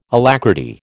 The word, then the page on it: alacrity: American English